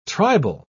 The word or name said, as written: tribal